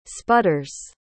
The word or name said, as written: sputters